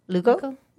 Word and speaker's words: Lugo: I don't know if that's how you say your last name or not. L-U-G-O. Lugo? – Lugo.